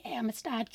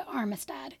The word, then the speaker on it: Amistad: As it turns out, I kept mispronouncing Amistad in that episode. I thought there was an R somewhere in there. I'm not sure how that happened. My notes definitely did not have an R, but in my head, there was an R. There isn't. It is the Amistad case, not the Armistad case, or however I pronounced it.